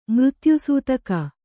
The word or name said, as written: ಮೃತ್ಯುಸೂತಕ